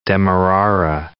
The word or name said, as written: demerara sugar